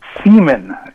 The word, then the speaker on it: Seaman: Seaman. Yeah, like, you know, the ejaculate. – Right, okay. – Oh, really? Spelled like semen? – What's that? – Like spelled S-E-M-E-N? – Yeah, just like that.